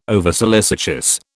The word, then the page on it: oversolicitous: British English